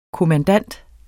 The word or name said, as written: kommandant